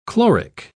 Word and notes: chloric: British English

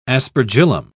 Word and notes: aspergillum: British English

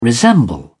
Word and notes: resemble: British English

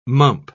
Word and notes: mump: British English

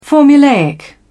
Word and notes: formulaic: British English

